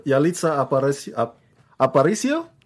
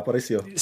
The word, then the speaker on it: Yalitza Aparicio: Oh, Yalitza Aparicio? There you go, Aparicio.